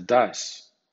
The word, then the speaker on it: does: O bien G-O-E-S suena goes según la norma 4, pero D-O-E-S suena das.